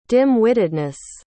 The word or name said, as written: dim-wittedness